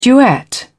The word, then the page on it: duet: British English